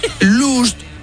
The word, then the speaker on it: lost: Porque vamos a ver, last, L-A-S-T, es... – último. – Lost, L-O-S-T, es... – perdido. – Y lost... que no se pronuncia lust, sino que se dice también lost.